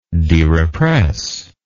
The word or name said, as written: derepressed